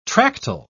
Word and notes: tractile: British English

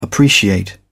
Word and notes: appreciate: British English

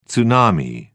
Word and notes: tsunami: British English

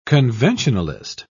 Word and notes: conventionalist: British English

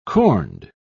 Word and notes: corned: British English

